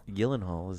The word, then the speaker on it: Gyllenhaal: Gyllenhaal is the proper way to pronounce Dutch? – His last name.